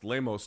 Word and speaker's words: Lemos: UFC Fight Night, Lemos, or I'm told, by the way, it could be pronounced Lemos, depending on where you're from in Brazil, versus Andrade.